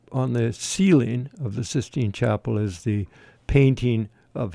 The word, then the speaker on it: Sistine: the cistern. Did I pronounce that correctly? And it was pretty Sistine. That's what it is, Sistine Chapel.